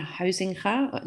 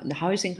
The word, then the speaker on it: Hasinger: So my name is Nicolene Hasinger. Hasinger has a very Dutch name, so it's very hard for non-Dutchies to pronounce it.